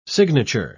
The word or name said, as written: signature